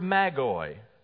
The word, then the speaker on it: magoi: And if you did that in English, that would be M-A-G-O-I, magoi. And really, its root meaning, without the plural ending on it, it would be magos. We would spell it M-A-G-O-S. That's why sometimes we hear people talk about the magi. All right, that's really kind of a non-standard pronunciation of this Greek word.